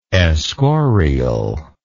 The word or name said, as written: escorial